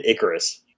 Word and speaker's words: Icarus: Do you call it Kid Icarus or Kid Icarus? – I mean, I've always said Icarus. That's how I've heard it pronounced because it's like the Greek legend, but I don't know.